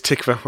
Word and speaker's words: Tykwer: The director, Thomas Tykwer, I've wrote it down phonetically as well. It is Tykwer, I'm correct.